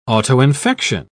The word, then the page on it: autoinfection: British English